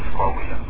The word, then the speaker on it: Coahuila: is the correct pronunciation Coahuila? – Yes, Coahuila.